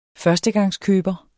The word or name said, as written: førstegangskøber